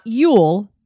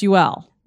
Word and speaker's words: Yule: It's not y'all. And apparently it's not Yule. It's UL.